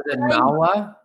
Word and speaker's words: Mawa: Was it Mawa? Is that how you pronounce? Is it Mawa? – Yes, local potent gym.